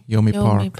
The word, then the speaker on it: Yeonmi: And I'm not sure if I'm pronouncing her name correctly, but I think it's Yeonmi. – Yeonmi Park. Yeah. I think I first heard about her incredible story on Jordan Peterson's podcast.